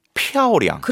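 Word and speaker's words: 漂亮: 입술이 탁 튕겨 줘야 돼요. – 표. – 표량. – 표량. – 그렇죠. 이 지금 그거 기억해야 돼요. 타이 표량라.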